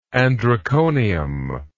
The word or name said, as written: androconium